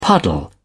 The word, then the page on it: puddle: British English